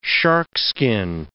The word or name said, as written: sharkskin